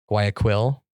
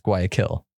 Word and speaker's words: Guayaquil: And then after, I think the next 10 times I said it throughout that night when people were talking to me, I kept saying Guayaquil, because that's kind of how it's spelled. And then I was like, oh, did I say it right? I don't know if, I don't even know how to pronounce this place. Finally looked it up and yeah, it's Guayaquil.